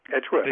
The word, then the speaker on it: Edgeworth: And I'm not sure I'm pronouncing the name correctly, but Francis Isidro Edgeworth? – Edgeworth, absolutely, yeah.